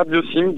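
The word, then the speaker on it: Biocym: Alors, il y a d'abord Biosim, je le prononce bien, Biosim. – Ouais, c'est ça, Biosim.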